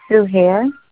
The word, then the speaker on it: Suhair: Sue Hare. – How do you spell that? – S-U-H-A-I-R.